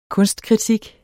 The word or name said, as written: kunstkritik